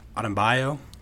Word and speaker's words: Adebayo: Adebayo? – Yeah.